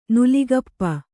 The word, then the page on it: ನೆಲಗಪ್ಪು: kannada